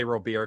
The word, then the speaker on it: Robert: I always say Robert because that's the way he talked about how it's supposed to be pronounced.